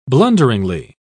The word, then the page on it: blunderingly: British English